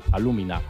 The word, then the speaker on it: alúmina: La principal exportación de Jamaica es alúmina, perdón, no sabía cómo pronunciarlo, con acento en la U, alúmina.